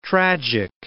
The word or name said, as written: tragic